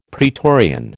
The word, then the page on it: praetorian: American English